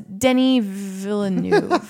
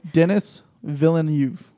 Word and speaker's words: Denis Villeneuve: I thought it was Denis Villeneuve. I thought the IS was silent, but that's why I'm not trying. – Denis Villeneuve. Villeneuve. It's French.